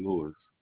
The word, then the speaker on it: Lewis: No, I'm Richard Lewis. – R-I-C-H-A-R-D. – Yes, L-E-W-I-S.